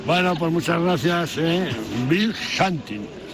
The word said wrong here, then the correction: Wild Hunting: Está bien pronunciado. Wild Hunting.